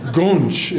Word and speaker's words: goonch: Gunch is the pronunciation.